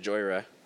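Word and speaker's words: Jhoira: I've always called it Jouar. – I always thought it was Joyra. – Joyra? – Could be. I have no idea. – It rhymes with Moira.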